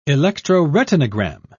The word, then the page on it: electroretinogram: British English